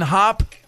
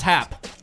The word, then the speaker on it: Happ: So for the good today, how do we say his name? Ian Hop of the Chicago Cubs or Ian Happ? – I think it's Happ. – Happ.